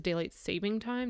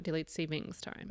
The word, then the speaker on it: daylight saving time: It's daylight saving. There is no S. Let's just do that as a PSA right now. It is daylight saving time, not daylight savings time.